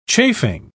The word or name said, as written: chafing